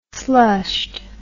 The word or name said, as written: slushed